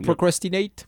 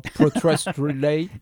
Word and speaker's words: procrastinate: Yeah, I can't say that word. I never say it right. – Oh, protrastinate? – Yeah, something like that. I don't put the right syllables at the right place.